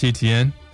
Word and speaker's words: Etienne: And I think it'll be that ETN or whatever. Is that how you say his name? I'll go with him. – Travis Etienne.